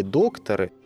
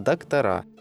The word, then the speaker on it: доктора: Так вот, если на рубеже 19-20 веков были докторы, то сейчас единственно верный вариант – доктора, с ударением в конце слова.